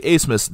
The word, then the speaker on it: Abmas: Now, again, I say Asmus despite the fact that his last name is spelled A-B-M-A-S.